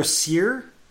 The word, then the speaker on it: Cyr: Tyler Cyr, C Y R, but pronounced S E E R.